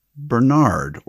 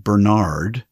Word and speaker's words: Bernard: First, it is spelled B-E-R-N-A-R-D, we would say in the States, Bernard or Bernard. And his last name is Foskett, F-O-S-K-E-T-T. Now, in this country, we say the name Bernard as it is spelled.